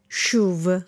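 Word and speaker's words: CHUV: Sentono una sh ma scrivono una ch e non sanno bene dove mettere le mani, quindi a rigore se lo pronunci come lo pronunciamo noi, quindi shuv, dovresti dire lo shuv, che sarebbe la forma corretta, inizia con un suono sh e la regola varrebbe,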